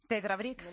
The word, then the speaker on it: Tetra Brick: Tetra Brick.